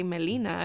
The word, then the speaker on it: Melina: Like when I say my name, I say Melina. I don't say Melina or Melina, you know?